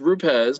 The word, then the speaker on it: Rupes: Rupes or Flex? – What? – Rupes or Flex? – You mean rupees? – Yeah, the correct way to say it is rupees, but yes, rupees or Flex.